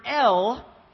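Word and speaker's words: El (אֵל): And the word that is used here is the Hebrew word El, just like the letter L, but it's spelled E-L.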